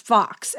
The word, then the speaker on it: Faux: And then the other one I highly recommend is called Number Go Up, Inside Crypto's Wild Rise and Staggering Fall by Zeke, I think it's pronounced Fox, F-A-U-X.